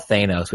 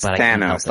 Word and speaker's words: Thanos: And it has a bad guy called Thanos, who I call Thanos, which I know is wrong. – Yeah, it's Thanos, like Van. – Right. It's a short. It's not a long A.